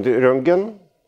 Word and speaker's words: Рентген: Все началось в недавнем прошлом, в конце XIX века, когда Вильвин Корен Рентген, именно так правильно произносится его фамилия,